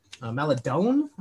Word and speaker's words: Maledon: But another guy on Oklahoma City Thunder, Theo Maledon, Maledon, I believe. – You know how... It is.